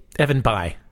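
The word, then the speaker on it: Bayh: Evan Bay? – Evan By.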